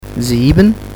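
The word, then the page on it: 7: language French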